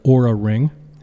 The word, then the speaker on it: Oura: If you want to get a little more spendy, you could just buy yourself an Oura ring. It's spelled O-U-R-A, Oura ring,